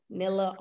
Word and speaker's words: Nilla: know how to spell it, because my first name, Nilla, is just my last name backwards. So real easy for you. Nilla All In.